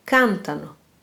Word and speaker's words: cantano: Loro cantano. – Dankjewel. Hier hebben jullie dus weer gehoord waar de klemtoon valt, cantano, en hoe ze vervoegt.